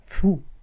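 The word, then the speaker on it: Fu (福): In China ist die Fledermaus ein Symbol für Reichtum und Glück, weil die chinesischen Wörter für Fledermaus und Glück bzw. Segen die gleiche Aussprache haben, Fu.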